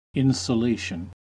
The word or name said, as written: insulation